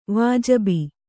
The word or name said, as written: ವಾಜಿಬಿ